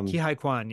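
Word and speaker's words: Ke Huy Quan: Kihei Kwan, yeah.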